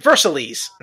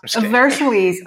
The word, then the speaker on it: Versailles: I don't know how to pronounce it correctly. – Mm-hmm. Versailles. – Versailles. Oh, okay. I didn't know the actual English. – It's not that English. I'm just being dumb. It's Versailles in English.